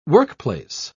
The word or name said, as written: workplace